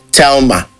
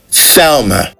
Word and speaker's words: Thelma: Do not say Thelma, all right? Now that's wrong. It Thelma. Next word, 30.